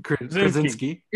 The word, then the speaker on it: Krasinski: And we obviously got, I can never pronounce his last name, but we got John Krasinski.